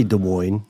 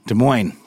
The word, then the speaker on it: Des Moines: You pronounce the S? – I say Des Moines. – Des Moines. Okay, I'm going to go with Des Moines, Iowa.